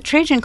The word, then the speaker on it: Trajan: Is it pronounced Trajan? – It's the Trajan Column, Trajan.